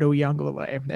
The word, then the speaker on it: Uiagalelei: We should talk about Uiagalelei, and I'm going to keep saying his name because I learned how to pronounce it.